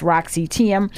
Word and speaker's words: Roxy Thiam: I am speaking with my guest, Roxy Thiam.